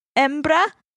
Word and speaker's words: hembra: Hembra? Embra? Should I say the H at the beginning? – Spell it for me. – H-E-M-B-R-A. – Yeah, I'd say embra.